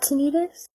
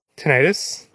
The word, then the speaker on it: tinnitus: There are multiple pronunciations. – But you weren't joking? – No, I'm looking it up right now. Tinnitus or tinnitus.